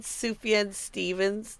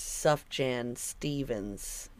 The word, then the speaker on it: Sufjan Stevens: You know how he said Sufjan Stevens? That's how you say it, right? Yeah. I was saying it for years, Sufjan Stevens.